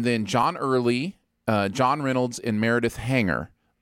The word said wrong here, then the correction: Hagner: uh, or Hagner, excuse me, uh, are the four main characters.